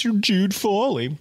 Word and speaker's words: Jude Fawley: I mean, it rhymes with Rude Jolly. It's Jude Fawley.